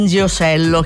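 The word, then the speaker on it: Ndegeocello: Nziosello, che io spero si pronunci così, ho fatto una fatica, l'ho pronunciato bene,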